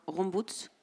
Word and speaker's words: Rombouts: Et puis un troisième personnage, c'est le docteur Rombouts, c'est comme ça qu'on prononce, qui